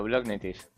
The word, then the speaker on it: Blognitiv: Hoy vamos a hablar con director de desarrollo de negocios de Blognitiv. Creo que se pronuncia así. Buenas tardes. – Muy buenas tardes. Sí, correcto, Blognitiv.